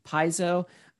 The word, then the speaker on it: Paizo: Pazio? I never know how to say it. Pazio? – Paizo.